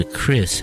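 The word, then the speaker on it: kris: Isn't that called a crease, or is it called a criss? How do you pronounce it? – Crease. You got it. Crease.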